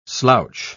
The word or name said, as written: slouch